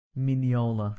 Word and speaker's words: Mignola: Silent G? I think it is, isn't it? – Mignola. – Yeah. – Like filet mignon.